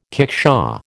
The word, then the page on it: kickshaw: American English